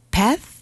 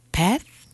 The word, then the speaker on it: path: 베스, 번데기 발음. 베스, 베스.